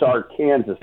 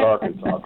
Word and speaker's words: Arkansas: Kansas, you told them that they pronounced the name of their state wrong, that it's Arkansas, not Arkansas, correct? – Absolutely, I did.